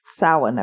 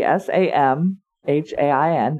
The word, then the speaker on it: Samhain: So the the story that he tells is that Salin it's pronounced Samhain, everybody. S A M H A I N. It's pronounced Samhain. Welcome to the Celtic language where we put letters where we don't need them.